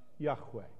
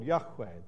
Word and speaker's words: Yahweh: The name that in English is normally written as Jehovah, but was probably pronounced Yahweh.